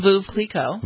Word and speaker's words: Veuve Clicquot: Is it Veuve Clicquot? Is that how you pronounce the champagne? – Veuve Clicquot.